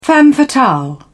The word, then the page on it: femme fatale: British English